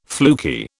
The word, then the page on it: fluky: British English